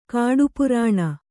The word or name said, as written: ಕಾಡುಪುರಾಣ